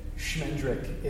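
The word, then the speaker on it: Schmendrick: Shmendrick is a pretty, and that is how it is pronounced.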